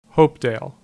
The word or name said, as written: Hopedale